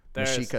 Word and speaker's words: Mexica: Mexica, it's spelled like Mexico with an A.